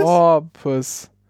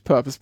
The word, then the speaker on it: porpoise: Porpoise. Porpoise, nicht Purpose, Porpoise.